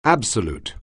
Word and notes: absolute: anglais

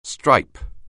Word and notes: stripe: anglais

